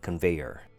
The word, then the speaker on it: conveyor: For the first word, we have conveyor, three syllables. Kun actually sounds more like C-U-N, like from the word cunning. Vei sounds like hey, but with a V instead, and er. Sounds like your typical E-R ending sound, conveyor.